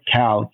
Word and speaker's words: Kall: My full name, Robert Kall, K-A-L-L. – Shell, everybody. – It's okay if you don't pronounce it correctly. I know it's a weird thing.